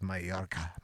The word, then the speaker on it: Mallorca: And it's spelled Majorca, so it's Mallorca.